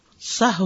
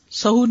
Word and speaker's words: سہو: سہ ہوتا ہے سہو نہیں ہوتا سب لوگ اپنا تلفظ بھی درست کر لیں سہو جیسے لغو ٹھیک